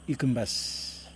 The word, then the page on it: ikim bas: Yokoim